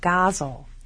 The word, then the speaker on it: ghazal: I have to ask you about a pronunciation, because to our graduate students, they study the Persian form ghazal. – Yes. – And that's what they call it. – Yes. – And you, how is the correct pronunciation? – Ghazal.